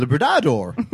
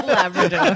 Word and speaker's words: Labrador: And is it Labrador or is it Labradador? – Labrador. The way that you would think. – Henceforth, it shall be known as Labrador.